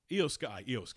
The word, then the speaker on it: Io Sky: Io Sky technically is how you pronounce her name.